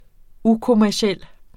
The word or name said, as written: ukommerciel